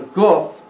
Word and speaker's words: Guf: It is also the place that we call the Guf, G-U-F or G-O-P-H, however you want to spell it to announce it as, pronounce it as Guf.